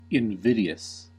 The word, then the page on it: invidious: American English